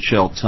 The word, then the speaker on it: Shilts: Mr. Chair, my name is John Schultz. I'm the administrator for the Workers' Compensation Division, and it's spelled S-H-I-L-T-S.